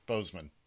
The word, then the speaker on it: Boseman: But pretty much, Chadwick, now Boseman is the way you pronounce his last name, right? – I thought it was Bowman. – I thought it was B-O-S-E. – Oh, it is. You're right. Boseman.